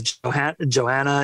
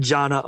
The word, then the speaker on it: Jonna: I think Jonna was the big winner of this episode because her first name gets mispronounced all the time. – Yeah. – As Joanna and stuff like that. And they said Jonna a bunch for a national audience,